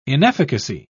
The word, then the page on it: inefficacy: British English